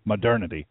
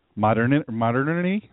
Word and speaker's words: modernity: Modernity. It's a real word, and you really do pronounce it that way, modernity. Modernity? But what? It's modernity. It really is that.